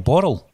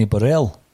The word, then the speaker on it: Borrell: His name is probably Johnny Borrell, but we just call him Johnny Burrell. – That's the Scottishness in us, eh? Just add an extra syllable.